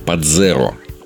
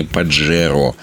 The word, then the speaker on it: Pajero: В Японии же слово «пахера» произносят как «падзеро». В русском языке также закрепилось произношение «паджеро», от которого уже образовалось множество различных сленговых прозвищ.